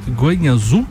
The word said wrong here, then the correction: Guanyu Zhou: Só, só, atenção, desculpa. É Guanyu Zhou.